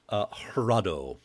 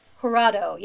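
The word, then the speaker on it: Jurado: Hurado. – Hurado, yes.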